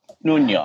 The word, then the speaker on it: Nuno: Is that right? Are we pronouncing that correctly? – Vidal, do I have a pronunciation for him? – Vidal Nuno. – Nuno. All right.